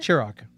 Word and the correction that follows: Ciroc: It's French. – It's Ciroc. – Oh, is it Ciroc? It maybe it's Ciroc.